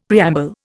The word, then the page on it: preamble: Indian